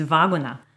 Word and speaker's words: Laguna: The Polish pronunciation, I believe, is Waguna.